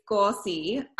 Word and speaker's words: Gauci: It's Gauci. Is like the best way to remember it is like saucy, like a saucy hot dog.